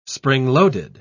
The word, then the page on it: spring-loaded: British English